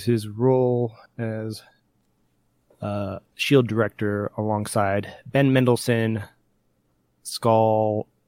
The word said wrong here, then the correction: Skrull: however you pronounce it. Supposed to be Skrull. You're missing, yeah, that's an, what I was like, you're missing an R.